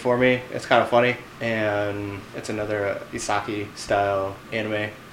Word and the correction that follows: isekai: Isekai. – There we go. Yeah, I've been saying it wrong for a while.